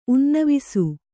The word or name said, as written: ಉನ್ನವಿಸು